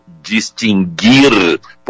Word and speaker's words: distinguir: O que ocorre é que não havia trema no verbo distinguir, portanto, não se pronuncia o U. Em nenhuma forma verbal.